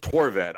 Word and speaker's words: Horvat: It's just Torvet. I don't know what you were going for the first time.